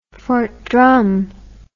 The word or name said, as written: Fort Drum